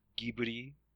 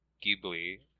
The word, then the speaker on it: Ghibli: It would seem, however, that Miyazaki either mistook or deliberately corrupted the pronunciation of Ghibli, which is meant to have a G sound as in gift, rather than a J sound as in giraffe.